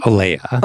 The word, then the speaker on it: Olea: Am I saying that right? – Olea. – Olea. I knew that wasn't right. Either, there was a little more fancy like schwing right there in the middle of it that I was missing.